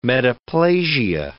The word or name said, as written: metaplasia